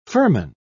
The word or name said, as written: firman